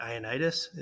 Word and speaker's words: Ioannidis: I may butcher the name, but Ioannidis, is that? – Yeah. Yep. That's right. Okay. Nailed it.